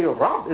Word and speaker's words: Rab: Actually, Rab is really one syllable, isn't it? Rab. When you say the Rabbun is two syllables, when you pronounce the sign at the end of the letter, but it's actually Rab, one syllable letter, but so rich in meaning.